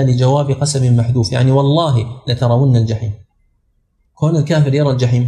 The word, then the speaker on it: لترون: بعض الناس ما يستطيع ينطقها هنا لأن الواو مضمومة إما أن يهمز وإما أن يغير فلعلكم تتدربون على النطق الصحيح نسأل فقط عبد العزيز اقراها لترون